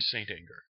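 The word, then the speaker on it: St. Anger: From what I've heard of Stanger or Saint Anger, however they pronounce it. – Saint Anger.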